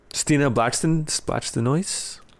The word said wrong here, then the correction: Blackstenius: Blackstenius. – It's sounds like it's spelled.